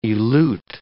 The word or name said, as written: elute